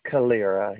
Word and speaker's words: Calera: I think it's in what's called Calera. I'm not sure how to pronounce it. – Calera, yes.